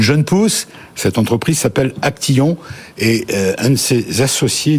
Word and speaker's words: Actiyon: Alors vous êtes directeur marketing de Actillon. – Actillon. On dit Actillon. – Oui, ça s'écrit A-C-T-I-Y-O-N.